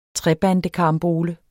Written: trebandekarambole

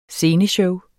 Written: SCENESHOW

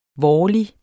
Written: vårlig

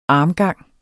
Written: armgang

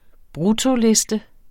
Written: bruttoliste

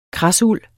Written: kradsuld

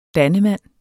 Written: dannemand

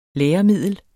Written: læremiddel

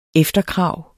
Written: EFTERKRAVETS

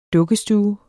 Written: dukkestue

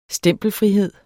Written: stempelfrihed

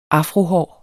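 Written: AFROHÅRET

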